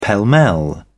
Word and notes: pell-mell: British English

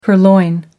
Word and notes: purloin: American English

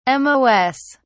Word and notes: mos: British English

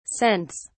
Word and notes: cents: British English

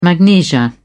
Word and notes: magnesia: British English

